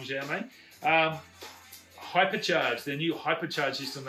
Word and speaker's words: Xiaomi: Xiaomi, I think it's Z-X-I-A-O-M-I, Xiaomi. Yeah, I'm pretty sure that's how they pronounce it.